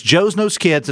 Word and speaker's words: JOZNOZKIDZ: Now, again, it's Joe's Nose Kids. It's spelled JOZNOZKIDZ, and you can find that on Facebook if you'd like to get involved and learn more information about it.